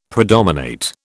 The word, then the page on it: predominate: British English